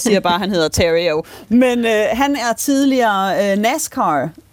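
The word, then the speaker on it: Theriault: Austin, og så er også hans efternavn meget fransk, og jeg skulle lige til at kalde på dig, Stephanie, for at finde ud af, hvad man udtalte. Men heldigvis, så siger han selv bare Terrio.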